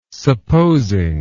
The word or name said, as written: supposing